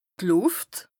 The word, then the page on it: d' Lùft: Bas-Rhin; Strasbourg